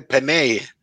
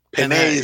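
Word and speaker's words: Penei: ayer escuché por primera vez cómo lo pronunciaban Peney. – Peney Sewell.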